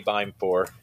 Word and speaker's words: Beimfohr: So K Beimfohr at Twitter is always a good place to get in touch with me.